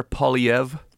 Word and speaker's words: Poilievre: Well, it's French, so it's I don't think he's French, but the name's French. Pierre Poilievre. – Poilievre. – So sort of a weird R, silent R.